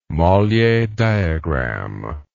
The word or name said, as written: Mollier diagram